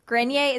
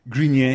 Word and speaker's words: Grenier: I feel like Melissa Joan Hart and Adrian Grenier. Is that how we're pronouncing him? – Grenier? – Yeah. – I'm going to say greener.